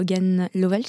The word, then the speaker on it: Hogan Lovells: Hogan Lovells, je prononce bien.